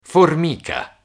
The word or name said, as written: formica